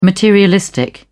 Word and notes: materialistic: British English

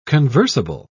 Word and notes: conversable: British English

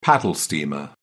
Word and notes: paddle steamer: British English

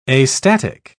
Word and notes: astatic: British English

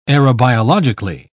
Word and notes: aerobiologically: British English

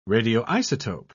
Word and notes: radioisotope: British English

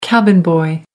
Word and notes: cabin boy: American English

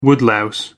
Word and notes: woodlouse: American English

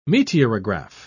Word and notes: meteorograph: British English

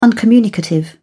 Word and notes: uncommunicative: British English

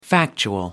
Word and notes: factual: American English

